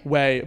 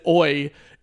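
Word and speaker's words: Ouai: Way. But it's spelled O-U-I... I don't know what the full, but it looks like I pronounced it oi until I pronounced it oi. – Yeah. – Until someone like finally told me how it was pronounced. And I was like, I'm just going to pretend I never, ever pronounced it oi.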